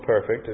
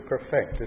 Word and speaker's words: perfect: To perfect, as we might use it ourselves with that pronunciation, it's not perfect, it's to perfect.